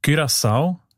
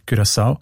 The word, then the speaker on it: Curaçao: Das Land, habe ich gelernt, spricht man eben mit «ü» aus, wegen, weil Holländisch dort auch eine wichtige Sprache ist. Und im Holländischen ist das «u» offenbar häufig als «ü», Curaçao.